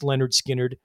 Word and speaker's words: Lynyrd Skynyrd: And second, they created a masterpiece that, in this curmudgeon's opinion, is one of Southern rock's three most essential recordings, alongside Lynyrd Skynyrd's first album, pronounced Lynyrd Skynyrd, and the Allman Brothers Live at the Fillmore East being the other two.